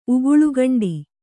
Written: ಉಗುಳುಗಂಡಿ

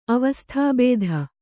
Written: ಅವಸ್ತುಭೂತ

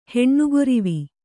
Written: ಹೆಣ್ಣುಗೊರಿವಿ

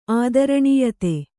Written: ಆದರಣೀಯತೆ

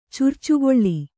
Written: ಚುರ್ಚುಗೊಳ್ಳಿ

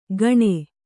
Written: ಗಣೆ

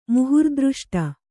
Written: ಮುಹುರ್ದೃಷ್ಟ

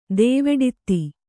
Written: ದೇವೆಡಿತ್ತಿ

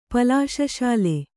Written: ಪಲಾಶಶಾಲೆ

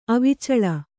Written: ಅವಿಚಳ

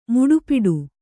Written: ಮುಡಿಪಿಡು

